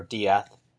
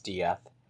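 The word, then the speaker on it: Díaz: Moises Diaz, or Diath, with a Z at the end, so the correct pronunciation would be Moises Diaz,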